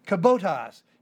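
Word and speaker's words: kibotos (κιβωτός): Now, the Ark in Greek is kibotos, K-I-B-O, long O-T, short O-S, K-I-B-O-T-O-S.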